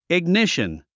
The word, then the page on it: ignition: iɡníʃən; イグニション